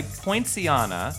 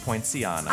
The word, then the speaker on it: Poinciana: And it sounds like poinsettia. – But it's pronounced poinciana.